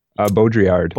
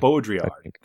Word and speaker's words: Baudrillard: Baudrillard? – Baudrillard. – Baudrillard.